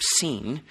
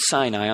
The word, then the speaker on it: Sin: which is between Elim and Sinai, on the 15th of the second month after they had come out of Egypt. Now, I want you to note the pronunciation, desert of Sin, not desert of sin.